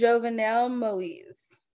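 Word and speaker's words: Jovenel Moïse: So Google has a pronunciation, and it's Jovenel Moise.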